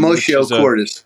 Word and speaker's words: commotio cordis: commodio cordis. – Um, commotio cordis. A, commotio, cordis. – I was close.